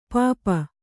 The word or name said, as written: ಪೆಪ್ಪು